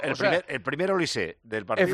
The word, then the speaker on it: Olise: Por cierto, nos están diciendo varios oyentes, que en la TEF-AN francesa pronuncian Olise. – Olise. – Con acento en la E.